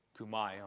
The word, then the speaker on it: Talitha kumi: Talitha kumi. Kumai, I'm sorry, I should pronounce it correctly, whether you guys knew it or not, which means, little girl, I say to you, arise.